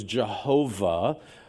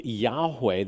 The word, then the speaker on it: Yahweh: Some people refer to it as Jehovah. It's probably better pronounced Yahweh.